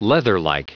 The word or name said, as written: leatherlike